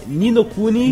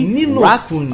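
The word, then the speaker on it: Ni no Kuni: É Ninokuni, Ninokuni.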